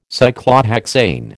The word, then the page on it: Cyclohexane: American English